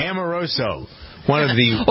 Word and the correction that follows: Omarosa: Or Omarosa.